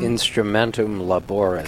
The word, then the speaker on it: Instrumentum Laboris: I do want to address one thing, because if you read, how do I pronounce it? Instrumentum Laborum? – Instrumentum Laboris.